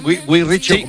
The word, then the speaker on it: Guy Ritchie: Lo he dicho bien, Guy Ritchie. – Sí, exactamente.